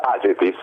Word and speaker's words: Agefis: Dove va l'accento? – Age. – Io Agefis. – Agefis. – Io dico Agi, come lui, così non sbaglio.